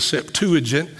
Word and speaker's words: Septuagint: And they wrote the Old Testament over again in Greek language, which is referred to as the Septuagint, or you may pronounce it the Septuagint.